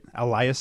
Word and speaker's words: Elias: Per Elias, that's how you say it, right? Elias Sports Elias. – Bureau. – Yeah. Elias, all right.